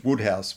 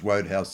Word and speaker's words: Wodehouse: The correct pronunciation is Wodehouse, but I grew up with him in my head as Wodehouse, so I can't change.